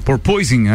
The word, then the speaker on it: porpoising: me ajuda na pronúncia, proposition, é isso? O, ao ah, porpoising, é, que é aquele que faz é, o, o kick do carro, é isso? – Isso.